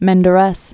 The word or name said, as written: Menderes